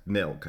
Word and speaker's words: Mylk: They have something called, well, it's pronounced milk, I guess, but M-Y-L-K, and they use that to make their oat pale ale.